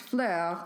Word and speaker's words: Phlur: The first one I'm going to talk about is Fleur, P-H-L-U-R, but it's pronounced Fleur, like the French word for flower, which is something I can almost pronounce better than Italian, but not really by much.